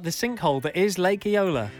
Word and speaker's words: Eola: Lake Eola. Have I said that right? – Yeah. Yeah. I love how you're asking me.